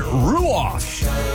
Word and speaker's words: Ruoff: Ruoff Music Center this Saturday. You want to go set sail? Hey, I got a pair of tickets right here, right now. You see, to text the keyword Ruoff, and that is spelled R-U-O-F-F.